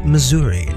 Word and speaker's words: Missouri: In 1821, Missouri became the 24th state to join the Union, and that's how you pronounce it, Missouri, not Missouri, like a doofus.